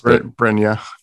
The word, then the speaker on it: Brynje: Brynje.